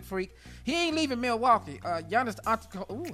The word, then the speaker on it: Giannis Antetokounmpo: Giannis Antetokounmpo. The Greek Freak is going to stay in Milwaukee. That's all we need. Giannis Antetokounmpo.